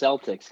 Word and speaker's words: Celtics: I'm pretty sure it's pronounced Celtics, guys. It's a basketball team. – And a soccer team. – No. – Or football team. Yeah. So I think it's not a hard C, I'm pretty sure.